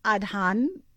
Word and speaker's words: Adhan: I mean, I want to say the track is The Black Adhan, but I'm correct. – Yes, you are correct. That is the pronunciation, yes.